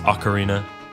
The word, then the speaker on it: Ocarina: Before we continue, yes, I know that it's pronounced Ocarina, but I can't unlearn what I have learned to say at this point.